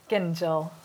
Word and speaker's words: Gingell: Going to introduce everyone who probably they probably don't need any introduction, but Skye Gingell, hard G followed by soft G, just in case anyone was wondering, is the culinary director here and one of the most acclaimed chefs in Britain.